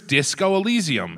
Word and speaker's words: Disco Elysium: Here's Disco Elysium.